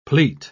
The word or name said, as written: pleat